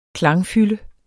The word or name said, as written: klangfylde